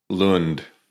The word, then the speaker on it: Lund: Lund.